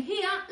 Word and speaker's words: here: En here la H es sonora. Es decir, ese sonido de la H inglesa en que limpiamos unas gafas. Here. Here. No here. Here. La J no existe en inglés. Es here.